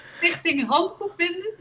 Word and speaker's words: Stichting Handboekbinden: She's a bookbinder, book restorer, and a member of the Dutch Society of Bookbinders, Stichting Handboekbinden. Am I pronouncing it correct? – Yes, Stichting Handboekbinden, that's correct.